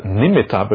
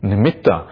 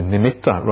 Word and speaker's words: nimitta: We often pronounce it as nimitta, but really the proper pronunciation is nimitta. It's double T, so it should be nimitta rather than nimitta.